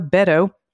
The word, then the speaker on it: Beto: Here's my pod pet picture of my boy Beto, pronounced like Beto O'Rourke, for Texas, not Beto, like the abbreviation of most unoriginal name Beethoven for St. Bernard.